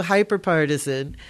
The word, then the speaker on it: hyperpartisan: Partesian, part, part, I'm from Louisiana. We don't pronounce anything properly. Our first language is Creole, and it messes us up. So, hyperpartisan.